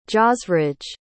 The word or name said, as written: jasraj